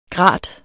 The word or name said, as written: grat